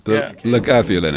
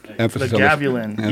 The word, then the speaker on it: Lagavulin: Lagavulin, it's pronounced. – How you say it? – Lagavulin. – Lagavulin. You right.